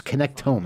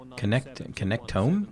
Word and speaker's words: connectome: It looks like connect to me, but it's Connectome, I think is how you're supposed to pronounce it. Have you ever said that word? – Connectome? – It's spelled connect to me, but Connectome.